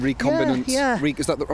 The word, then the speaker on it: recombinant: It's this recombinant ecology. And the process. – Do you want to explain again? Recombinant. Am I saying it right? – Recombinant. Yeah.